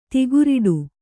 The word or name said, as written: ತಿಗುರಿಡು